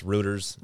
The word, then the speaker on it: Reuters: It was, I never know how to pronounce this. Routers. – Reuters. – Reuters. Thank you.